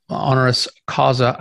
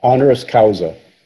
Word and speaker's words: honoris causa: In 2012, he was awarded the degree of Doctor of Sacred Theology, honoris causa. I never know how to say these Latin words. How do you say that? – Honoris causa.